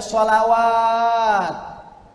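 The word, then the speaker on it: sholawat (صلوات): Yang kedua, alif, lam, sod, lam, wawu, alif, tegak, tak maftuhah. Dibacanya sholawat.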